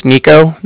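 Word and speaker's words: Niko: Yeah, like I said, my name's Nico. That's N-I-K-O.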